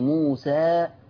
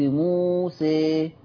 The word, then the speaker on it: موسى: قلت زي موسى، قلت حكم الألف هنا الفتح، ولما جيت قرأتها بشكل آخر أو بكيفية أخرى ملت بيها فقلت موسى، فأملت صوت الألف.